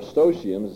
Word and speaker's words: Eustochium: In a letter to Eustochium, or Eustochium as some pronounce it, who's an aristocratic young lady, Jerome sets forth his ideas on marriage.